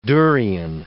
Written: durian

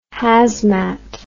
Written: hazmat

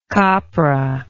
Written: copra

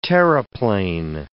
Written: terreplein

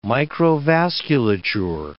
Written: microvasculature

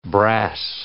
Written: brass

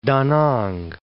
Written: Da Nang

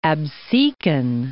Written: Absecon Inlet

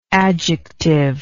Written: adjectively

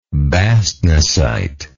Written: bastnaesite

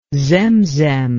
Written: zemzem

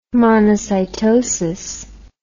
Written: monocytosis

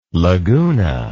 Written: Laguna

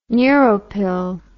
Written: neuropil

